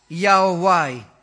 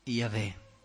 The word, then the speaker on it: Yahweh: E così, mettendo insieme Y, H, W, H e le vocali di Adonai, è venuto fuori Yahweh. E si è giunti così alla pronuncia Yahweh.